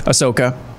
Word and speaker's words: Ahsoka: And then secondly, Ahsoka, is that how you say her name? – Ahsoka. – That episode with her, it was like they turned her into Batman.